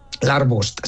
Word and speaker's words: Larvust: Lo he dicho bien, ¿no? Larvust. – Larvust, sí, correcto.